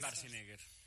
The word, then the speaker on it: Schwarzenegger: Es Schwarzenegger.